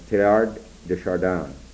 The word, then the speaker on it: Teilhard de Chardin: His name is Teilhard de Chardin, T-E-I-L-H-A-R-D. You don't pronounce the H. And it's Teilhard de Chardin.